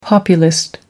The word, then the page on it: populist: American English